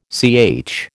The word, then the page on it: CH: American English